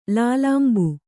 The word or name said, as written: ಲೋಲಂಬ